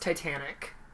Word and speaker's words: Titanique: I honestly don't know how it's pronounced because I know it's spelt Titanique. – Titanique. – Titanique. But I think it's just pronounced Titanic.